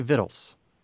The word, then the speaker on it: victuals: But we still don't pronounce it with the C, right? – Correct. – So is it spelled with the C? – It is. V-I-C-T-U-A-L-S mm-hmm. is like the formal spelling of the word, but you still say Vittles.